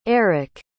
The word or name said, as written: eiric